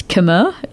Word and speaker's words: Khmer: And the other thing that I heard was, Khmer, which is my understanding of how Cambodians pronounce Khmer, like the language.